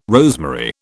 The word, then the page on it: rosemary: British English